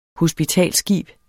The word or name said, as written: HOSPITALSSKIB